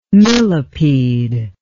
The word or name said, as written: millepede